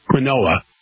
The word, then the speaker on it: quinoa: Now, it's spelled Q-U-I-N-O-A. It looks like quinoa. Quinoa. It's pronounced quinoa.